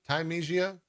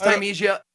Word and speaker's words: Thymesia: Time Thymesia Thymesia. – I didn't even think there was another way to pronounce it. Like it looked like you know, parsley, sage, rosemary, and thyme.